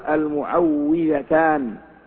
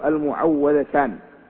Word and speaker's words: المعوذتان: ولعلنا لم ننسى ما ذكرناه بالأمس أنه يقال المعوذتان ولا يقال المعوذتان فما يجري على ألسنة العامة المعوذتان أو المعوذتين فإنه غير صحيح في النطق لأنهما يتعوذ بهما ولا يعوذان.